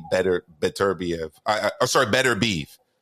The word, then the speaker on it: Beterbiev: I'm telling you, my opinion is it's going to play out for Beterbiev or Better Beef, however you say his name. – Yeah, I asked a Russian journalist, and he told me it's actually Better Beef, because I was going back and forth with D-Style before.